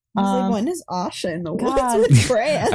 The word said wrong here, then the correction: Osha: It's spelt with an O and I still say the ah sound, you know?